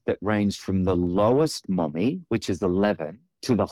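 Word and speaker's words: momme: mommy count, and that's M-O-M-E, but it's pronounced mommy.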